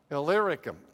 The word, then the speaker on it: Illyricum: Illyricum. There you go. Well, I'll go with that. That's way up north in Asia Minor. Here's Jerusalem down here, Illyricum way up here.